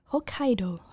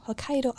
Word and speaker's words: Hokkaido: Ooh, ooh, apologies for the incredibly Anglican pronunciation of that. Hokkaido, Hokkaido Island.